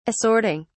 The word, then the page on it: assorting: British English